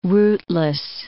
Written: rootless

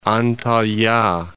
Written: Antalya

